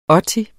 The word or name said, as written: otti